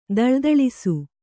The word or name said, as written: ಥಳಥಳಿಸು